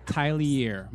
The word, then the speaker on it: Tylier: Tylier.